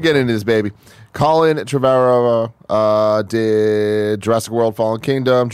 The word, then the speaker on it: Trevorrow: am I saying his name right? – Yeah, Trevorrow. Yeah.